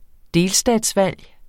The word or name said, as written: delstatsvalg